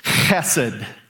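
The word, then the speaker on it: chesed (חסד): So I'm going to start there, and I want you to look in your crosswalk notes the Hebrew word is pronounced like this chesed.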